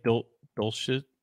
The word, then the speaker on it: Dulcich: I think Dulcich, yeah, you pronounced it correctly.